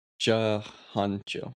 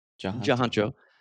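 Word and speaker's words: Tjahjanto: VHS 2 features a largely different group of directors: Jason Eisner, Gareth Evans, Timo Tajanto. I'm sorry if I mispronounced that. – I think it's Jahancho. – Jahancho.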